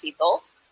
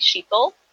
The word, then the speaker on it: Sheetal: So my name's pronounced Sheetal. But then there's lots of Indians that pronounce the H, so they'll call me Sheetal.